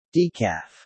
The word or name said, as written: decaf